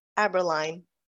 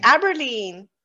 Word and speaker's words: Aberline: Everyone pronounces it wrong and never asks. Nina Daisy Aberline. – Okay, but I would think most people wouldn't get it wrong. Like, I have no. They say Aberline.